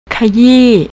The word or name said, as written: kayee